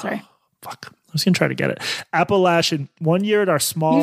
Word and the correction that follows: Appalachian: You just mispronounced it. It's not like Appalachian. Appalachian. – Appalachian.